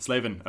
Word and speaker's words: Slavin: Did I pronounce that right? – Slavin. – Slavin. Okay.